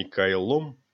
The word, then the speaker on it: Кайло: Фамилия у него была такая – Кайло, ударение на первом слоге».